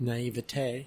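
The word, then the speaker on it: naïveté: Katie's biggest flaw is probably her, what is it, naivety? Naivety? – Mm-hmm. – Did I say that right? – Naivete.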